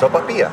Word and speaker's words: sopapilla: Sopapilla? Is that how you say it? – You're killing it. – I don't think I am. I'm learning. You speak better Spanish than I do. Tell me how you pronounce that. – Sopapilla. – Sopapilla.